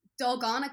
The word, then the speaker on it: Dalgona: Dalgona coffee.